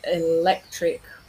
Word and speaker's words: electric: So for instance, we have electric with the stress on the second syllable, but electricity with the stress towards the end.